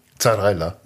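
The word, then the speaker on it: Zarrella: Zarella. – Das ist Zarella mit Z. – Okay. Z, wie wir Deutschen das aussprechen, so heißt der.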